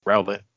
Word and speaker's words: Rowlet: That's a weird way to pronounce Rowlet. – You just gotta roll it a little bit more. – Rowlet.